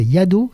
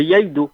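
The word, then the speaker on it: iaido: Oh, j'arrive pas à le prononcer, c'est yaido. – Voilà, le yaido.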